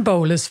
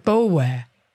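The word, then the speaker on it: Boulware: Now, in the previous episode, I said Barrett Bowler, but locally, they would pronounce it Bowler, so I'm going to go with Bowler.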